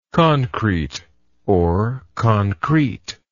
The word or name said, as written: concreteness